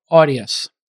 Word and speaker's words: Audius: But tell me, what is the vision for Audius, which is spelled, by the way, for folks who are wondering, A-U-D-I-U-S, Audius.